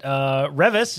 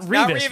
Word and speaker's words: Revis: We also learned that Revis is how you pronounce the author's name, not Revis, which we have probably been saying for literally years.